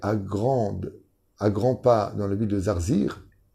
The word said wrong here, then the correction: Zarzis: Zarzis, c'est un peu dur à prononcer, Hein, qui se trouvait à Tunis.